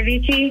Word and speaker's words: Avicii: The song Wake Me Up by Avicii.